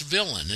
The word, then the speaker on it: villein: If you go in, Patrick, if you go into Black's and look under villain, it's spelled V-I-L-L-E-I-N. It's not spelled like it's pronounced. We pronounce it. Because it's French, and the origin was French from Europe. But it's pronounced villain.